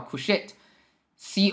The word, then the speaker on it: couchette: 那这个词怎么读呢？叫 couchette，couchette，意思就是。这个睡睡铺卧铺的意思就是有卧铺的车厢，这个呢叫 couchette，c o u c h e t t e，couchette。好，如果大家以后说到卧铺，你可以说我买的是 sleeping berth，或者你可以说我买的是个 couchette，啊，就是我是有卧铺的这个票的。